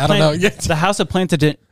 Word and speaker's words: Plantagenet: The House of Plantagenet.